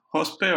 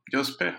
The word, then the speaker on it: Jospe: My name is Jospe, or Jospe, however you feel more comfortable pronouncing it.